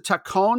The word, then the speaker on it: Tacón: The club was founded in 2014 independently of Real Madrid as CD, I'm going to pronounce it Tacón because they bothered to put in the acronym or the accent mark rather, but the whole name was an acronym.